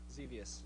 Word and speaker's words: Xevious: Xevious.